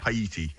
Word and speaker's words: Haiti: Haiti? I'm not even sure how you pronounce it. Haiti, is it? – Yeah. – Haiti.